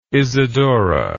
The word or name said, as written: Isidora